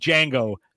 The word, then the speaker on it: Django: He's like, Django. The D is silent.